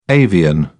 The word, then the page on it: avian: British English